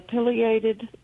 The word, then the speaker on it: pileated: pileated woodpecker. Now, I don't know if I said that correctly. – Yes, ma'am. – Sorry? – Yes, ma'am. That's perfect. Perfect.